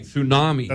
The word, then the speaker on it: tsunami: With the silent T and everything. Tsunami.